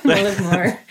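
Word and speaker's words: Mullaghmore: Well, we shorten it to Mully a lot, but yeah, Moloch more.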